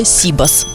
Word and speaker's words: сибас: Но у последнего есть тенденция к тому, что сделают равноправное ударение. То есть Сибас. Очень часто люди произносят именно такое ударение Сибас. Но вообще-то пока в словарях только одна норма – это Сибас. Даже знаю почему. На Сибас перенесли перенесли ударение на первый слог.